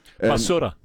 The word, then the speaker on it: basura: I saw the video about taking out the trash that's kind of La Barusa, is it? Did I say that correctly? – Basura. – What's that? – Basura. – Basura. Okay.